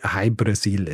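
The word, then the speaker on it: Hy-Brasil: Jahrhundert jetzt, wenn in Irland drüber geschrieben wird, dann wird gerne mal die Insel zum Beispiel Brasil geschrieben oder O-Brasil oder eben Ui-Brasil, was ausgesprochen quasi so viel wie High-Brasil ist.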